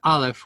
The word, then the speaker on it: Aleph: You have Ayin, which is actually, you have Aleph, which is kind of just a hard glottal stop.